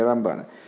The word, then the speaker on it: Рамбан: Рамбана а, для того, чтобы не путать э, Рамбана и Рамбама, э, мудрецы специально решили, что Рамбана будут говорить с ударением на второй слог, а Рамбама на первый.